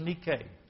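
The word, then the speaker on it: Nike (Νίκη): In Greek it's pronounced Nike.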